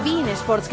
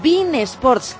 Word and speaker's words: beIN Sports: Con Bin Sports, qué bien lo dices, ¿eh? Tú ya has aprendido y el resto del mundo lo mismo. Tiene que pronunciarlo así, Bin Sports.